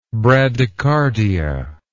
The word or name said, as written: bradycardic